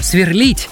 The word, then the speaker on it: сверлит: Для того, чтобы верно поставить в нем ударение, необходимо подобрать проверочное слово. А это инфинитив, то есть неопределенная его форма сверлить. Так как в нем акцент ставится на гласную «и», значит, там оно и остается. Поэтому правильно говорить «сверлит», «сверлит сосед».